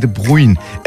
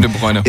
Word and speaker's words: De Bruyne: Ich habe den Namen, glaube ich, schon falsch ausgesprochen. – Ja. – Und ich glaube sogar beide Namen falsch ausgesprochen. – Nein. – Erling Haaland war noch richtig, ne? – Ja. – Kevin De Bruyne heißt es, glaube ich.